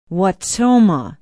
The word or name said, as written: Wautoma